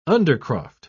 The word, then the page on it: undercroft: British English